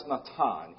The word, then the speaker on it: Natan: The word there is a Hebrew word that we pronounce Natan, N-A-T-A-N.